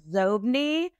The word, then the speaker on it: Xobni: Zobni. Is that what it's pronounced? – Yeah, it was originally pronounced Zobni, but Bill Gates pronounced it Zobni, so they changed the pronunciation of it.